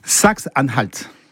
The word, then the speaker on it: Saxe-Anhalt: Saxe-Anhalt.